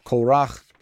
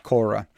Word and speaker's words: Korah: I just noticed I'm kind of like going back and forth between normal and Hebrew pronunciation of the name, but you know the guy, Korach, Korah.